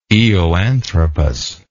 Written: eoanthropus

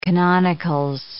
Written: canonicals

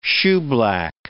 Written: shoeblack

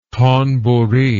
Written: thonburi

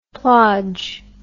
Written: plodge

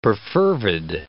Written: perfervid

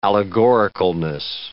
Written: allegoricalness

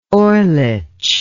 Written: orlich

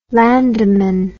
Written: landammann

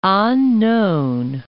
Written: An Nhon

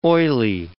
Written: oily

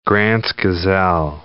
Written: Grant's gazelle

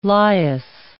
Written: Lias

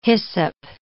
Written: hyssop

